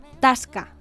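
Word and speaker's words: tasca (тоска): La palabra es tasca, ¿vale? Se escribe tosca y se pronuncia tasca. Tasca.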